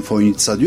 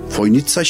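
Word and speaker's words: Fojnica: Ama çözemediğim şey, telaffuz olarak ben Foynitsa diyorum, öyle duydum. Fakat gelen misafirlerin hepsi o C'yi S olduğunu bilmiyor, TS olduğunu bilmiyor ve Foynica'ya işte gelmekten şeref duydum gibi şeyler zikrediyor. Merkeze bir yazı yazdım. Dedim ki Foynici olarak yazılan kelime telaffuzu Foynitsa şeklindedir, düzeltilmesi.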